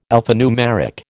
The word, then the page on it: alphanumeric: American English